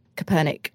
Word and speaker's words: Kaepernick: and that you know goes alongside with Colin Kaepernick.